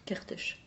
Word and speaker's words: Kertész: On continue par une petite citation de, comment on prononce, Imre Kertész. – Kertész. C'est un écrivain hongrois qui a eu le prix Nobel il y a une dizaine d'années maintenant. – Kertész qui dit ceci. Je pense à Imre Kertész, une nouvelle fois.